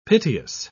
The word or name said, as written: piteous